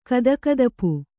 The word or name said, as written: ಕದಕದಪು